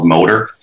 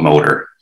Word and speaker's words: MOATAR: Our simplest and most affordable mixed reality solution is called MOTOR. I love these acronyms. We spell it MOTOR, M-O-A-T-A-R. It stands for Mechanism of Action Through Augmented Reality. So we call it MOTOR.